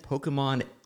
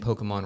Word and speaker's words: Pokémon: In fact, I'm probably saying Pokemon wrong. Isn't it more like technically it's Pokemon or whatever? It's more of that accent on the E, especially when you watch the anime, so they show it.